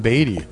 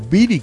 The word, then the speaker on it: Beatty: Nada que ver con Warren Beatty. O Beatty, que también está correctamente pronunciado.